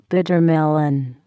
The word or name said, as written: bitter melon